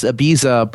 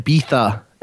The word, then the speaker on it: Ibiza: That said, my favorite part of this song is the hotly contested way to pronounce Ibiza, because you would think it's just Ibiza, but the British like to pronounce it Ibiza. And so if you're feeling extra pretentious, and it has something to do with the way the Z is pronounced in Spanish.